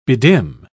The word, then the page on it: bedim: British English